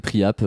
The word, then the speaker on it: Priape: Sur Spotify, on peut écouter Priap. – Voilà, comment tu l'écris? – Priap? – Ouais. – P-R-I-A-P-E, comme ça voilà. – Se prononce.